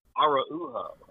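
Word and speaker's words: Araújo: Um, yeah, uh, that was that was a challenging one because the first time I thought I was like, "Is it Araujo?" And they were like, "No, it's Araujo," and I was like, "Yeah, oh, yeah, what?"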